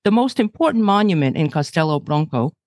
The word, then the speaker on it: Castelo Branco: Okay, you make a SH sound in the first word. Castelo, the okay. O is pronounced as a U. Castelo Branco.